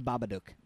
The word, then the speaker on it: Babadook: Is that how you say it? Babadook. – The Babadook. – Babadook? – Babadook. The Babadook. – Babadook. – Yeah.